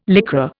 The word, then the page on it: Lycra: Indian